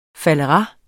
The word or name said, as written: faldera